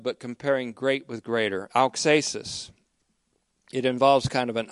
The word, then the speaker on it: auxesis: This method is called auxesis, as we've seen before, A-U-X, long E. It's a kind of a Greek word, but it's auxesis. It is a Greek word, auxesis.